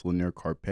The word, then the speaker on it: Lanier Carpe: It's like the most commonly mispronounced name is Lanier and then Carpe. – Yeah. – What's the worst pronunciation you've seen? – Lanier. Or the lanyard that you have for your ID.